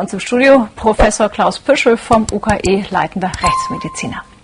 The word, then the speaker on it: Püschel: Püschel. – Puschel.